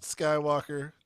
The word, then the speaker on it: Skvwalker: It's spelled SKV, right? – Yeah, how would you say that? Just wanna Skywalker. Yeah. – He's learning, guys. He's learning. – Yeah. Skvwalker.